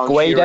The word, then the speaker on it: Guedes: It's Guedes, I think. So it's like how I sort of phonetically put it was, it's like G'day, but G'way, Des.